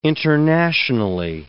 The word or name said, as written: internationally